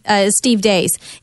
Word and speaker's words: Deace: The other person I forgot, and I've been telling you to listen to him because I love Steve Dace. It's spelled Deace, but he's out of Iowa, and he's does a syndicated show, but I love listening to him.